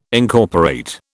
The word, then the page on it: Incorporate: British English